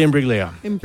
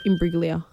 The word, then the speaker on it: Imbruglia: Natalie Imbruglia. Put it down. I'll give you that. – Soft G. – Imbruglia.